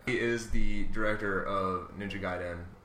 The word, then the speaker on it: Gaiden: And Ninja Gaiden.